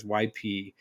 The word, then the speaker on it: Ypsilanti: I think you're right. It's Ypsilanti because it's Ypsilanti.